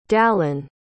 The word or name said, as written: dalon